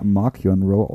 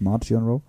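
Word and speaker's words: Marchion Ro: Ich meine, also im Audiobook, äh, im, im Hörbuch wird der Mark Keon Rowe ausgesprochen und nicht Mark Rowe.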